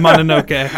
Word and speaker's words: Mononoke: Mononoke.